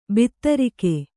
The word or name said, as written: ಭದ್ರಕ